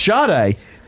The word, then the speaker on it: Sade: Oh, so it's Sade.